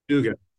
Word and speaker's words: Dugin: Dugin.